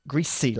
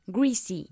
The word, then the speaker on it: greasy: Greasy. La S es más fuerte. – Greasy.